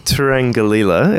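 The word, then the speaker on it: Turangalîla: Well, so it's the Turangalila. Is that, there we go.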